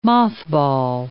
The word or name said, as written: mothball